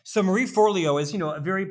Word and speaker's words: Forleo: Marie Forleo. – Yes. Well said. Marie Forleo.